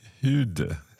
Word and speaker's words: hyrde: So we've got hyrde. Quite tricky to say that. – Hyrde. – Hyrde. So do you not really pronounce the R? – Do you not hear an R?